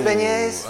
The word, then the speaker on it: beignet: With beignets? – I'm just playing. – Beignets? – Do what did yeah, I, why do I keep saying it wrong? Beignet? – Yeah.